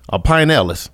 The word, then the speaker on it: Pinellas: I have a caller calling in from Clearwater, Florida, and then I have another young man calling in from the Pine Ellis County area. Pinellas. I'm going to get it right.